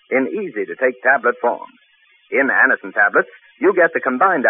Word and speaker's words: Anacin: Remember the name, Anacin. It's spelled A-N-A-C-I-N.